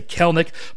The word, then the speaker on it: Kelenic: Now to the young outfielder, Jared Kelenic. First of all, his name. Almost everyone says Kelenic, but the Mariners broadcasters all say Kelenic.